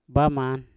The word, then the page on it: baman: Mpur